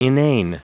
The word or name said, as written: inane